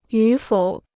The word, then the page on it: 与否: yǔ fǒu